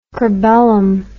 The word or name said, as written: cribellum